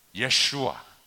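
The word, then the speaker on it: 예수와 (ישוע): 더 정확한 발음으로는 예수와 예수와 구원하실 자.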